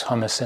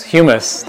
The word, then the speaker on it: humus: It's moist, it's rich, it has hummus in it. Not hummus. You know, the other one. I probably pronounced it wrong, right? How's it pronounced? Hummus? Humus? Humus, that one.